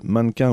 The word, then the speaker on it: MNNQNS: Alors, ça s'écrit MNNQNS, c'est du rock français déjanté et chanté en anglais. Attention, ça se prononce avec l'accent gallois, si possible, mannequin ou mannequins.